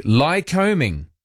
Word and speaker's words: Lycoming: Lycoming. Although there's no, it's not like combing your hair, not that kind of combing.